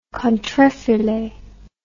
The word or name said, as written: contrefilet